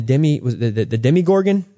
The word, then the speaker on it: Demogorgon: But in Stranger Things, we had what was called the Demogorgon.